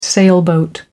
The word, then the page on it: sailboat: British English